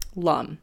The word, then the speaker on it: lum: So the seed mantra of the lowest one, they say, is lum. Rhymes with thumb, lum, okay.